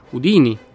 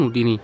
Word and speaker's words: Houdini: Cominciamo intanto col dire che il suo nome si pronunciava Houdini, con l'accento sulla prima I e non Houdini.